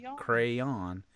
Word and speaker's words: crayon: And yeah, crayon. But crayon. And see, I'm making it a one syllable, and it's apparently a two syllable word.